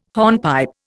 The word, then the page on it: hornpipe: Indian